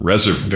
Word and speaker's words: reservoir: You become a reservoir. War, how do you say that? Reservoir. – Very French.